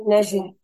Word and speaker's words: Najla: Nashla, sí.